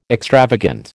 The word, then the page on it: extravagant: American English